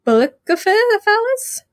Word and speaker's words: Bucephalus: At age 12, he proved his equestrian skill to everyone when he tamed, Bucephalus? – 5 stars. Just absolutely perfect pronunciation.